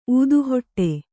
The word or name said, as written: ಊದುಹೊಟ್ಟೆ